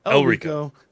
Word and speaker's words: Elrico: If you're looking for great designs, maybe you can go talk to our friend, Larico. I'm saying his name wrong. Like Elrico. – I say. Elrico.